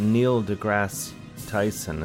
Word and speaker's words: Neil deGrasse Tyson: I mean, this is that we need that Neil deGrasse Tyson. Is that how you say his name? – I believe so.